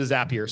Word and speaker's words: Zapier: So I think it's because people have the long A in API, and that's the, I think that's the whole reason, but the Zapier.